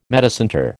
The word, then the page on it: metacentre: American English